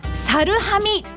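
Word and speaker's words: 猿喰: 福岡県北九州市門司区にある地名、猿を食らうと書いてサルハミと読むそうです。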